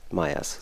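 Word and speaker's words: Seth Meyers: Und ähm, ja, ich glaube Seth. Nicht, Seth ja. – Meyers, so wird ausgesprochen.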